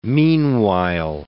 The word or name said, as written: meanwhile